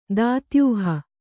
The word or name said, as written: ದಾತ್ಯೂಹ